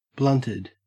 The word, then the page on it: blunted: Australian English